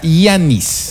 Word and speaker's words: Giannis: Se pronuncia Giannis. – G-I-A-N-N-I-S. – Giannis. El apellido, si no lo pronuncio, porque es... A-N-T-E-T-O-K-O-U-N-N-P-O.